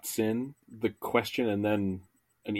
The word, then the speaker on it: hoatzin: I've also heard Hoatzin or Watson. Yeah, there are a bunch of different pronunciations, but I'm going to go with Hoatzin? – Yes. – Like Watson and Holmes Watson? – More or less, yeah.